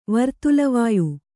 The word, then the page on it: ವರ್ತುಲವಾಯು: vartula vāyu